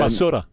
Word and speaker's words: basura: I saw the video about taking out the trash that's kind of La Barusa, is it? Did I say that correctly? – And, basura. – Basura. What's that? – Basura. – La basura. Okay.